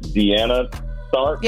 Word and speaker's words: Deanna Tharpe: And I so much appreciate Brother Patrick Muhammad joining us, as well as Jamie Harrison and is it Deanna Tharpe? – Yes.